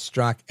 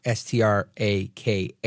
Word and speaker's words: Straka: Yep, that's at Brandon Strock, and that's Strock, Straka, silent A at the end there.